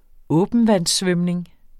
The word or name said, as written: åbentvandssvømning